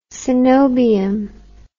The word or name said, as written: coenobium